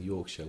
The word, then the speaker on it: Yorkshire: Can I just say, Travis, that you are pronouncing Yorkshire well? Because Americans usually go for the Shire. – Yorkshire.